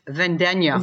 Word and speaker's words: Vendegna: Wow, I wonder, Dar, if I'm going to say your name wrong. Vendega? – No, wrong. – Fail. Please introduce yourself to your fellow Rangers of the Dork Forest. – My name is Darlene. My last name is Vendenya.